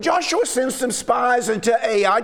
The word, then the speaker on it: Ai: Now, that's the way I'm going to pronounce it. I've heard it pronounced different ways I or IE. I'm going to say AI mainly because that's the way I learned it when I was a little boy.